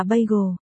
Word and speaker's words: bagel: Phải đọc là bagel, bà cầu, bagel, chữ cầu phải uốn lưỡi lên, bà cẩu, stupid, Lục Kiều Kiều đột nhiên phát âm rất chuẩn.